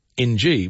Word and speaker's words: Ng: which is Ing, they say pronounced Ing, so she pronounced it Missing. – Yes, which is rather cute. It's a Vietnamese name. – Yeah. – And you just pronounce it Ng.